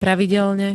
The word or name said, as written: pravidelne